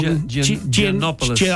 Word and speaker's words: Giannopoulos: Nick Giannopoulos. I knew I'd say it wrong. – Giannopoulos.